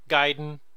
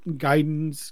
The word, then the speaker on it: Gaiden: I still have my Super Nintendo copy of the Ninja Gaiden trilogy. 1, 2, and 3. Gaiden. I know it's properly pronounced Gaiden, but we grew up calling it Gaiden.